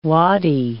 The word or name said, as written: wadi